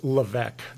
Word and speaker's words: Levesque: It's Levesque. The S is silent.